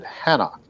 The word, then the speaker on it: Enoch: But there's two Enochs, which I'm probably jumping a little bit ahead, but he names the city after his son Enoch, and some people pronounce it Hanok.